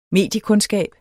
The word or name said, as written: mediekundskab